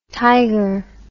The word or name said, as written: tyger